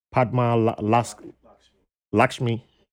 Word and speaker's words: Padma Lakshmi: No, it's on Hulu with the chef, Padma Laxma. I think that's how to say her name. I think I butchered her name. Padma Lakshmi. Yes. Okay.